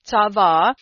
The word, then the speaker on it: tzava: That word charge there is Strong's 6680, and it's actually tzava. That's how you pronounce it, tzava, and it means to constitute, enjoin, appoint, command, or send a messenger, you see.